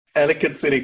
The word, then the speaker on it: Ellicott City: How do we pronounce this city in Maryland? – Ellicott City, Columbia area.